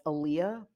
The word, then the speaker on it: Aaliyah: We kind of knew her as Aaliyah, but her name was actually pronounced Aaliyah. So like Muhammad Ali, so Aaliyah.